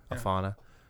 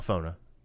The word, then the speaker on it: Alphona: Vaughan, Adrian Alfona. I say that right? – I think so. – Alfona. – Yeah. – Alfona. – A L P H O N A.